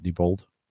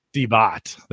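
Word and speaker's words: Deebot: Diebold? – EcoVac Diebot. – Diebot.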